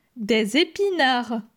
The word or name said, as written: Des epinards